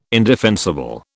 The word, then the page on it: indefensible: British English